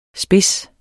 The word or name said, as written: spids